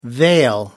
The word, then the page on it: vale: American English